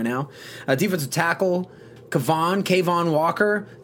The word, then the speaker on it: Cavon: So Savon. – Like the French pronunciation. – Savon.